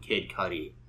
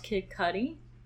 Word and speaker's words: Cudi: So it's pronounced Kid Cudi? – Kid Cudi. I said Kid Cudi. – No, that's how it's pronounced. Like, asking. – Yeah, it is. – Okay, I'm really shook. I've just never heard it pronounced that way. You thought it was Cutie? Kid Cudi. – I know, it's Kid Cudi.